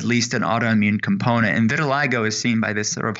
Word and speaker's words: vitiligo: vitiligo? Is that how you pronounce this discoloration on the arm? – Close. It's vitiligo.